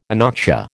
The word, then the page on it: anoxia: American English